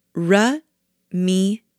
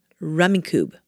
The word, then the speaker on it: Rummikub: So I just Googled how to say it. Rummikub. Rummikub.